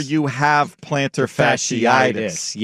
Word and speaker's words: fasciitis: Plantar fasciitis, pronounced fasciitis, he did it phonetically, thank you, occurs when the ligament gets irritated and inflamed.